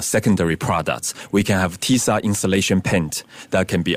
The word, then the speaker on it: TIISA: Do I pronounce it TISA? – TISA.